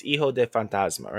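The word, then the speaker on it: Hijo del Fantasma: Drake Maverick versus Hijo de Fantasma, right? – Ooh, very nice pronunciation.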